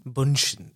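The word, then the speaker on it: Bündchen: I never know how to say her name, though. Bunchen? – Bunchen. Yeah. It sounds very German.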